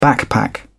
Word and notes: backpack: British English

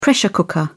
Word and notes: pressure cooker: British English

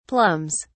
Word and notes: plums: British English

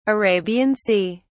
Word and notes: Arabian Sea: British English

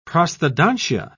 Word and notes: prosthodontia: British English

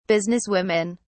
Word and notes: businesswomen: British English

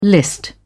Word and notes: list: British English

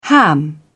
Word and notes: ham it up: British English

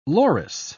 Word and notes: loris: British English

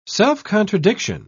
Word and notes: self-contradiction: British English

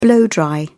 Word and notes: blow dry: British English

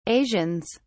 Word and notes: asians: British English

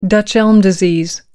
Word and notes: dutch elm disease: American English

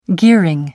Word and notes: gearing: American English

